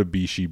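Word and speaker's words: Bixi: And a matter of fact, if you get the jump on this and you go to Bishi Beer, it's B-I-X-I. It's how you spell it if you're looking it up.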